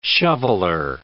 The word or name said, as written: shoveler